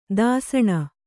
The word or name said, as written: ದೂಸಣೆ